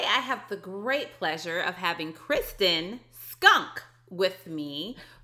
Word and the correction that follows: Shunk: Did I say it right? Not Skunk, Shunk. Sorry, Shunk. There we go.